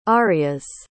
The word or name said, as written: arias